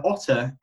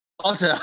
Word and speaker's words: Otr: Otter. – I thought he was going to have a different name. He didn't. He's just called Otter. – Because it's spelled O-T-R. That's like the cool version of Otter. Otter. – Yeah, it's like his rap name, his street name. – Yeah, Otter. O-G-O-T-R.